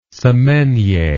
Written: semainier

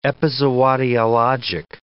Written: epizootiologic

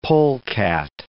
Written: polecat

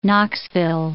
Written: Knoxville

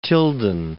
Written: Tilden

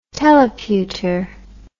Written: teleputer

